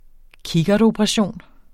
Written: kikkertoperation

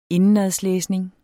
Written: indenadslæsning

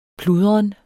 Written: pludren

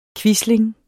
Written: quisling

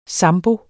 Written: ZAMBO

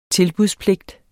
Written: tilbudspligt

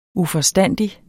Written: uforstandig

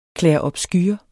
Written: clairobscur

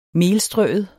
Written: melstrøet

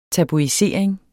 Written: tabuisering

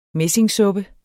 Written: messingsuppe

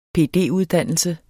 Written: PD-uddannelse

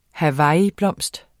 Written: hibiscus

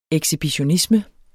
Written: ekshibitionisme

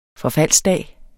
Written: forfaldsdag

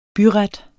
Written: byret

